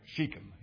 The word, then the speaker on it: Shechem: Shechem is the way it's pronounced. Shechem.